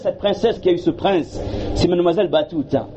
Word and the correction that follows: Batouli: J'ai bien prononcé. Comment ? – Batouli. – Batouli, excusez-moi, Batouli. Mais ce qui est symbolique, c'est que Mohamed Jibril, il est de parents d'origine indienne.